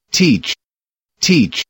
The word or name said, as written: teach